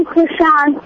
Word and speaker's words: Krishan: I'm Krishan.